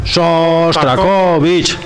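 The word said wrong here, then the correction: Shostakovich: Pero no es Sostrakovich, es Sostakovich.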